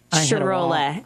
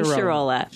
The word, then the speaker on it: Sharola: Sharola. Amy Sharola. – Sharola. Like you said to remember it, how? – My Sharona, but my Sharola.